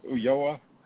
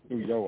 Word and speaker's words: Ulloa: Did I say that correctly this time, Ulloa? – I think so, but Ulloa. – You're asking the wrong guy. I learned that from you, I think the L.